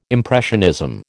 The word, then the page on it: impressionism: American English